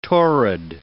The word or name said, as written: torrid